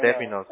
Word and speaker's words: términos: You know, I was born over here, so my Spanish isn't perfect, but no, términos. Términos. I'm going to remember that forever. No, gracias. Muchas gracias, Gustavo. I needed that. Jesus. Who knows how many times I've been saying that wrong, you know what I mean?